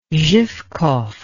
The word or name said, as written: zhivkov